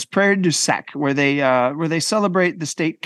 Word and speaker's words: Prairie du Sac: Maybe I say this wrong, but Prairie Du Sac. – Prairie Duchesne. – Duchesne? Du Sac, SAC.